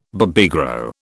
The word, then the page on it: Babygro: British English